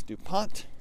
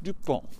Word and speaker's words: DuPont: It is a 1,000-acre garden previously owned by Pierre DuPont, as we would say here in the States, because we would pronounce the final T. I do not believe the French would have. I believe they just would have went DuPont and just kind of stopped with the N and went, that's more than enough consonants for one name. No need to pronounce that last T. So in the States, DuPont. In a French-speaking country, DuPont.